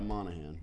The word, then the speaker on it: Monaghan: Moynihan. Monaghan? The G is silent, right? – Yeah, Monaghan.